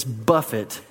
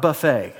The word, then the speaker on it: buffet: Now, real quick, the proper pronunciation of that third word is buffet, not buffet.